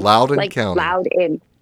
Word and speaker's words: Loudoun: They took it further this week with Glenn Youngkin had a rally in, I think, Loudoun, Virginia. How do you say that? – Loudoun County. Loudoun County. Loudoun, yes.